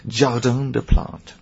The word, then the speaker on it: Jardin des Plantes: You go in the Jardin des Plantes. Gorahari, how would we say this? Jardin de Plantes.